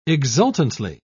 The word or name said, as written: exultantly